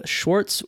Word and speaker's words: Swartz: Today I have Abe Swartz with me, and I'm pronouncing that correctly, I assume. – Close enough. Swartz. – Say it again. – No S-C-H. It's S-W-A-R-T-Z.